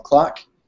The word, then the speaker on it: cloc: First is I just released a new Node module called Clock. But it's actually just a wrapper around something that somebody else wrote, Al Daniel, and it's spelled C-L-O-C, and it stands for Count Lines of Code or something like that.